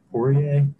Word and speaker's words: Poirier: Dustin Poirier, did I say his name? – Yep.